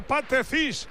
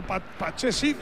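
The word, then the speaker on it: Pathé Ciss: Tocando cuero para Pache Cis. ¿Ha visto cómo se dice ahora? Pache Cis. – Sí, lo he entendido. Pache Cis.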